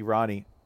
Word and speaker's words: Rahne: This is Ryder is pretty good because it does open up the possibility that Ricky Rahne, as we're now correctly pronouncing it for the first time, named his children after every member of the Paw Patrol.